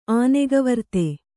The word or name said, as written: ಆನೆಗವರ್ತೆ